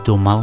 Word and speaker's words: Daumal: Ik heb het de hele tijd verkeerd uitgesproken. Het is Daumal met een L.